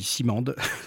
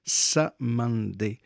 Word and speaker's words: Cymande: s'appelle Samande, ça s'écrit C-Y-M-A-N-D-E. Moi, pendant très longtemps, j'ai dit Simande, mais en fait, non, ça se prononce Samande.